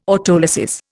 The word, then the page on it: autolysis: Indian